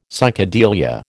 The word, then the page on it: psychedelia: American English